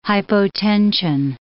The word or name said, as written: hypotension